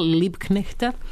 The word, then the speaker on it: Liebknechta: Karla Liebknechta, nisam dobro izgovorila, Liebknechta, njenog saborca.